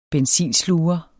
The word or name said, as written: benzinsluger